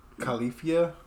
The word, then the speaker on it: Califia: Califia? – Okay, that's how I said it.